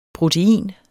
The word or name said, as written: protein